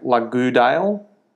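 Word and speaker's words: La Goudale: It's like Lagoudale. So it's L-A space G-O-U-D-A-L-E.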